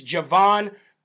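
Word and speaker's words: Jovan: It's not Hovan Pulitzer. It's Javon Pulitzer.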